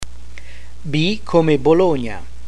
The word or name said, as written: B come Bologna